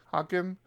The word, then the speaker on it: Hockin: How you pronounce that last name? – Hocken, who actually works on PHP Storm.